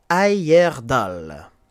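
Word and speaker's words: Heyerdahl: Et euh, notre personne d'aujourd'hui est un certain euh, Thor Ayerdal.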